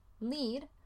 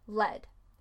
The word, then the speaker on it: lead: Se escribe L-E-A-D, pero se puede decir led o lead y puede significar el verbo guiar o mandar en el presente, como lead, o el sujeto plomo, led. Por ejemplo, the general gallantly leads his troops. Lead is dangerous to consume. Lead y lead.